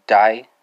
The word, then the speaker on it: deg: So with Norwegian, it's going to be die, right? Die, and that's spelled D-E-G, right?